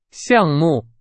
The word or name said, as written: 项目